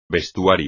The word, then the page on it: vestuario: espagnol